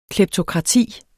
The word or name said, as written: kleptokrati